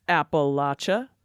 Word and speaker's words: Appalachia: in Appalachia, we pronounce it Appalachia, Rose Kinner, and Jim Finley. – And thanks to Jerry Irby Holden, Pear, that's P-E-H-R,